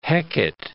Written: Hecate Strait

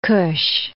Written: Kusch

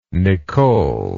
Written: Nicole

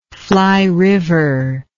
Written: flyriver

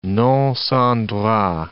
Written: non sans droict

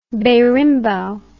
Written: berimbau

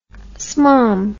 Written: smalm

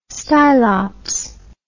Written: stylops

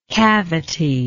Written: cavitied

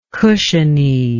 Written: cushiony

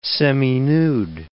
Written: seminude

